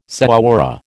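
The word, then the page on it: señora: American English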